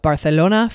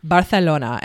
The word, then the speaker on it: Barcelona: In January 1939, the Republican stronghold of Barcelona fell to the nationalists. She's laughing at me. That's how you pronounce it in Spain, Spanish. Barcelona, and also Ibiza, FYI.